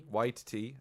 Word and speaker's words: Waititi: Waititi, apparently. – This is how you pronounce it. – That's how he says it.